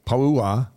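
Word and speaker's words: Po'uha: Pauha. – Pauha. – Pauha. There's a ha in there. Pauha. – Well, and here's the thing: you're supposed to pronounce every vowel, so it's P-O-U-H-A. So it's Pauha.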